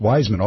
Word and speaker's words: Weissman: He told me his name was pronounced Wiseman, although it looks like Weissman.